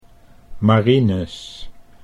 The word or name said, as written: Marinus